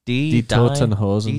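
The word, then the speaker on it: Die Toten Hosen: How do you pronounce it? Is it Die Totenhosen? – Die Totenhosen.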